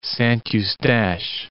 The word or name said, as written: Saint-Eustache